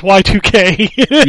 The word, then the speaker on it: YIIK: So talking about Yik last week, I'm pretty sure it's pronounced Y2K.